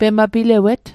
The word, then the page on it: pemapilawet: Mi'gmaq